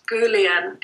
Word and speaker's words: Gulin: Okay, I checked this out with him, and it's Jared Gullion, and his last name is spelt G-U-L-I-N.